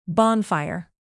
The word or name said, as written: bonfire